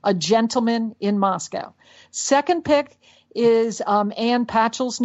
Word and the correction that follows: Patchett: new book, Commonwealth. – Oh, okay. Ann Patchett. Yeah. – Yep. Do I say her name right? Patchett. No, I forgot to cross my T's.